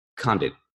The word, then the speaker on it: Condit: Condit.